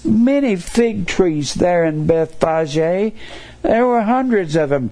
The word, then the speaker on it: Bethphage: And he was in Bethphage, B E T H P H A G E we would pronounce that pronounce that Beth Page in our day and time.